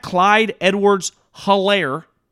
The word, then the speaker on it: Edwards-Helaire: Then you add this little running back, Clyde Edwards-Helaire, H is silent, as Al said, to the mix.